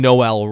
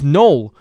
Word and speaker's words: Noel: So I'm even saying Noel wrong. It's Noel. Noel Achari. That's so weird. Okay. Noel Achari, my bad.